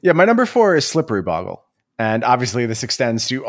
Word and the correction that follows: Bogles: all surrounding Bogles. I'm sorry, I said Boggle. I hate when I mispronounce that word.